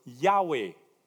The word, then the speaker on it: Yahweh: Y-H-V-H. Now, what had happened is that they had added some vowels into the word, and now we pronounce the word Yahweh, the same word that is pronounced in the English Jehovah.